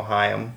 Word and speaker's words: Haim: But yeah, uh, Haim or Haim or whatever. How's it? Uh, it rhymes with rhyme, so Haim.